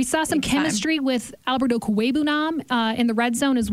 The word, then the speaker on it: Okwuegbunam: Okwuegbunam, I know.